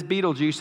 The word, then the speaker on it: Betelgeuse: Yes, it is Betelgeuse, and I did go to Google and made it play it for me to make sure I was saying it correctly.